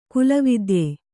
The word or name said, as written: ಕುಲವಿದ್ಯೆ